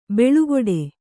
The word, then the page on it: ಬೆಳುಗೊಡೆ: beḷugoḍe